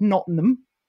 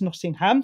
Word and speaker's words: Nottingham: Well, I like Rory anyway, but I liked him particularly this week because he was the only person this week that could pronounce Nottingham correctly. Everyone else said Nottingham, and that's not okay. It's Nottingham, so I like Rory for that.